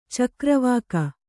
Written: ಚಕ್ರವಾಕ

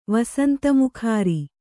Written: ವಸಂತಮುಖಾರಿ

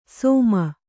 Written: ಸೋಮ

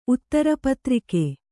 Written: ಊದರೆ

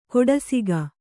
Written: ಕೊಡಸಿಗ

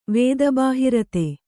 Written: ವೇದಬಾಹಿರತೆ

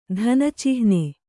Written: ಧನಚಿಹ್ನೆ

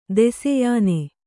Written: ದೆಸೆಯಾನೆ

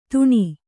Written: ತಣ್

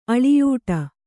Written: ಅಳಿಯೂಟ

